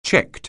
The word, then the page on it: checked: anglais